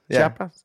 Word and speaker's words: Chiapas: ¿Lo dije bien? – Chiapas. – Chiapas. – Chiapas. – Chiapas. – No, güey. No, no, Chiapas. – Chiapas. Chiapas. – Chiapas. Yeah, there you go.